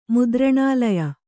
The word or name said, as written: ಮುದ್ರಣಾಲಯ